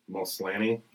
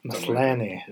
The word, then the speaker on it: Maslany: Maslany. – Maslany.